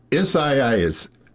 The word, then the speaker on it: Isaias: Isaias. Isaias.